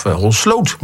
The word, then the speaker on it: Holsloot: Holsloot, of Holsloot moet ik eigenlijk zeggen, de klemtoon, 21,8.